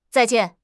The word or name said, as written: zai4 jian4